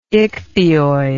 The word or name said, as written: ichthyoid